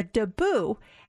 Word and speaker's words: debut: And a dabu is spelled D-E-B-U-T. So I thought it was a debut, but it's a dabu.